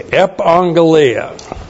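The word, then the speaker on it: Epangelia (ἐπαγγελία): Epangelia. That's E-P-A, two gammas together. You're the first one, you pronounce it like an N-G-E-L-I-A. Epangelia.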